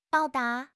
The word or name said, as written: 报答